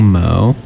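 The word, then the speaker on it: MØ: We started off with Charlie XCX with 3 AM Pull Up featuring Mo. Is that how you pronounce her name? – Yeah. – Mo.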